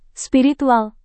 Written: spiritual

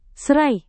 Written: serai

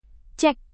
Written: cek

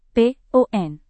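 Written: porsi

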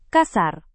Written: kasar

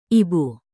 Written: ibu

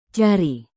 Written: cari

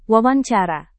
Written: wawancara

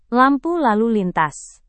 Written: lampu lalu lintas

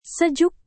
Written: sejuk

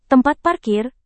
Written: tempat parkir

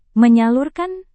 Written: menyalurkan

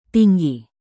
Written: tinggi

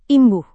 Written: imbuh

